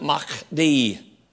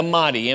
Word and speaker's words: Mahdi: They actually pronounce it Mahdi, but it anglicized, it would be the Mahdi, M-A-H-D-I.